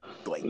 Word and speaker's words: Dwight: Dwan Howard. – It's just Dwight. – Dwight. That's it.